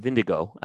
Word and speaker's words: Vingegaard: So the Tour was won by a guy by the name of Jonas Vingegaard, or Vindigo, I think actually is the way to pronounce it.